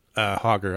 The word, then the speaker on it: Hager: Regards, Joseph Hager, rhymes with lager, Terra Alta, West Virginia.